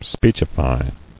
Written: Speechified

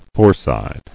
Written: Foreside

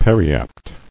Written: Periapt

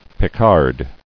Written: Picard

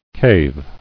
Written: Caving

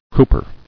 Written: Cooper

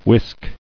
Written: Whisking

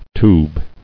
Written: tube

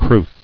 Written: Crwth

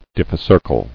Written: Diphycercal

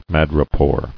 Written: Madrepore

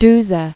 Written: Duse

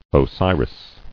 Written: Osiris

